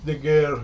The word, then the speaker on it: Daguerre: Louis Daguerre.